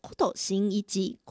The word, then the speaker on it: 工藤新一: 工藤新一他的日文发音念作 Kuto 新一吉，Kuto 新一吉。